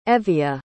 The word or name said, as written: Evia